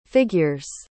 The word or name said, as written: figure's